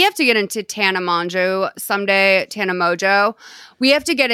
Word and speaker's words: Mongeau: It's Mongeau. It's French, the way it's like pronounced.